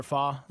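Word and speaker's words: phở: My favorite pho place, or pho, to pronounce it correctly, and we've had arguments about that before, is Indochine Cafe on South Hamilton, which I've mentioned on the show many times.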